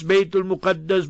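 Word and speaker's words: Bayt al-Muqaddas: Give us the fatwa and the verdict and decree that regarding baytul maqdis, baytul muqaddas, both ways you can pronounce it.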